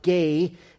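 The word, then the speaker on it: gay (γῆ): It's made up of the Greek letters gamma and eta, and we would pronounce it gay.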